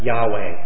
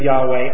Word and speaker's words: Yahweh: These words in Hebrew come from the Hebrew word without vowels in it, which is spelled YHWH, which is Yahweh. It's pronounced Yahweh.